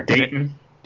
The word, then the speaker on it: Dayton: And then you would have to have something from the stadium tour, maybe Dayton or Dayton. I'm not sure how you pronounce it. – Daytona? – Or Dayton. – Dayton, Ohio. – Oh, Dayton. – Dayton, Ohio. Okay.